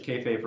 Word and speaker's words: kayfabe: Church is kayfabe for adults. – Oh, yeah. – Yeah. – No, I got you. Yeah, I got you. – Did I say that right? – Yeah, church is kayfabe for adults.